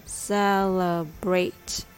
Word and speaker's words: celebrate: Let's practice how to say this word celebrate. Celebrate. Celebrate. The rhythm goes ta da da.